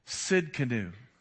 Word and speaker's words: Tsidkenu: This word here is Jehovah Tsidkenu. And I'll spell it for you. Tsidkenu. That's the Hebrew translation there. Jehovah Tsidkenu. The T is silent.